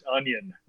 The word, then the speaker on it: Union: I apologize that I was pronouncing Union Berlin wrong and saying it like someone from Center Protect PEI, Union Berlin. – Yeah, that's. I was doing the same. I didn't know it was pronounced onion.